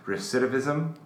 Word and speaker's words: recidivism: I'm going to butcher this word because I can't. Ashley, you'll probably correct me because you're our grammar police in here. Recidivism? – Yep.